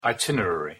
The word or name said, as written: itinerary